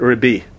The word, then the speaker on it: Rebbe: That, if you listen, everybody pronounces it differently. But you know, Sfaradim pronounce it Rabbi. But no, that's what in the Ashkenazic language is Rebbe.